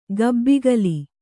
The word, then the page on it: ಕಬ್ಬಿಕೋಲು: kabbikōlu